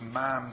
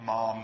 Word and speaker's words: ma'am: So, for example, the Queen should be addressed as ma'am to rhyme with jam, not mom to rhyme with farm.